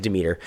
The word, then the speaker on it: Demeter: All right, Demeter.